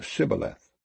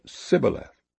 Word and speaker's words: Shibboleth: Then said they unto him, Say now Shibboleth. And he said, Sibboleth, for he could not frame to pronounce it right.